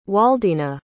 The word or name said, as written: Waldina